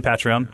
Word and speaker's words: Patreon: I would say Patreon. Kind of like a patriarch. Patreon. We're going to say Patreon.